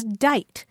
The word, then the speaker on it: dite: Another word you'll find mainly in Maine is dite. It's spelled either D-I-T-E or D-I-G-H-T.